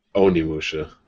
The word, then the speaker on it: Onimusha: And Omnimusha. – Onimusha. – Yeah, oh, I always say it wrong.